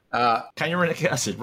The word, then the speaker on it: kynurenic acid: and you end up with this byproduct called kinurate, I don't know if I'm pronouncing it right. It was Rita, I don't pronounce it. – Kynurenic acid, right. – Yeah, kynurenic acid.